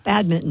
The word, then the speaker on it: badminton: No, we were talking about, I'm going to pronounce this incorrectly, badminton. You don't pronounce that N? – You do not. – No, badminton.